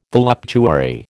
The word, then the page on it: voluptuary: American English